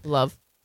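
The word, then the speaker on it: Lauv: Yeah, Love.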